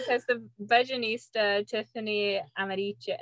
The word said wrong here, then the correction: Aliche: Ariche, how do you say Aliche. There we go. Aliche. I always put too many syllables.